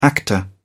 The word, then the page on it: actor: British English